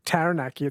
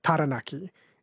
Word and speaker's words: Taranaki: Like, I noticed the rule, one that I tried is for Māori pronunciation, is they don't have the a sound like cat. It's instead of Taranaki, it's Taranaki, Taupo.